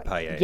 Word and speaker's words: paella: And then they're known for their, and I hope I'm saying it right, because paella? Am I saying it right? – Yeah, the paella.